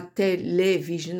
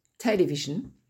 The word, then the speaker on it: television: television, on ne dit pas télévision, on dit television, addition, addiction, l'accent tonique sur deuxième syllabe, abortion, collection, America, j'ai bien dit America et pas K, hein.